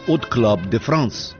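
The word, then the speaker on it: Hot Club de France: В 1932 году Уг основал «От Клаб де Франс», первое «H», французы не произносят. Отсюда и фонетическое «от Club de France».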